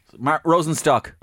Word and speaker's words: Rosenstock: Fair play, Rosencrantz. – Rosenstock. – Rosenstock, Rosencrantz, yeah. – Nobody gets my name wrong.